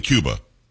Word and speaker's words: Cuba: It's not Cuba Gooding Jr. It's definitely Cuba. Okay. He pronounces like the country.